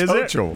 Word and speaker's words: Hochul: But that one, I know. It's Hochul.